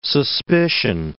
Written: suspicion

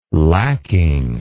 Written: lacking

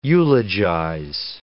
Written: eulogize

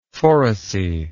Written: phoresy

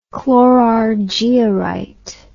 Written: chlorargyrite

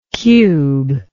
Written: cuber

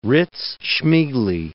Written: Rydz-Smigly

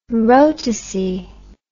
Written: rotacy

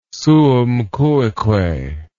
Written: suum cuique

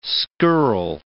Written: scurril

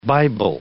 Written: Bible

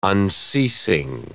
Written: unceasing

